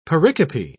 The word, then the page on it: pericope: British English